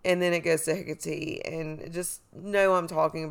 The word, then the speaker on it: Hecate: Her name, you can pronounce it Hecate. And some people even called her Hectate, I think.